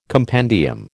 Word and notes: compendium: American English

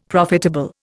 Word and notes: profitable: Indian

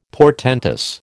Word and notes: portentous: American English

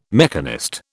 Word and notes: mechanist: British English

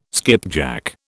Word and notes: skipjack: British English

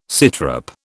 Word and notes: sitrep: British English

